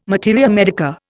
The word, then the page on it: materia medica: Indian